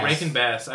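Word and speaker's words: Rankin-Bass: How's it pronounced? Raskin Bass? – Rankin Bass. – Rankin Bass. I always get confused about that.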